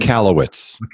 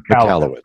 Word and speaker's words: Michalowicz: Michalowicz. – Michalowicz.